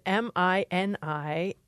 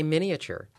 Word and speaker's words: miniature: Now, this word, miniature, how do you say that? How many syllables do you have? – I have four. I say miniature. – All right. I have three.